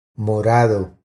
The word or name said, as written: Morado